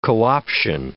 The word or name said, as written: co-option